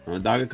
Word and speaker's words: Onondaga: And then you went to Onondaga? Am I saying that right? – Onondaga College, and then San Diego State.